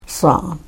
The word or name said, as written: saan